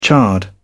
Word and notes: charred: British English

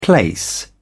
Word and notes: place: British English

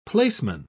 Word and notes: placeman: British English

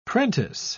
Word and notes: prentice: British English

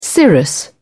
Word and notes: cirrus: British English

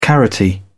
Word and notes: carroty: British English

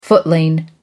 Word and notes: footling: American English

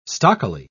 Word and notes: stockily: British English

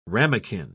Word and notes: ramequin: British English

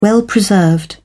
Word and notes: well-preserved: British English

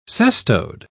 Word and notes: cestode: British English

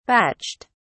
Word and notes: batched: British English

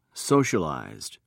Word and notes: socialized: British English

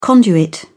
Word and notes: conduit: British English

